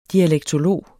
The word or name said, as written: dialektolog